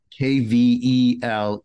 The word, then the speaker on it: Kvelertak: So Kvelertak.